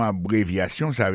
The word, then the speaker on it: abbreviations: Abbreviations, accent tonique la, tombe sou troisième syllabe la. Nan mot sa, gen quatre syllabes. C'est A, bon syllabe, bri, c'est deuxième syllabe là, vie, c'est troisième syllabe là, chan, c'est quatrième syllabe là.